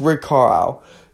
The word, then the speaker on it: Carlisle: I can't remember what I called his last name, like how I pronounced it, but it's Rick Carlisle.